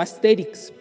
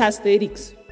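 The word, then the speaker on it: Astérix: Asterix, no sé, yo siempre lo he pronunciado como Asterix, porque lleva acento en la E, en la traducción española, aunque he escuchado mucha gente que le dice Asterix y Obelix. Bueno, es Asterix, según yo.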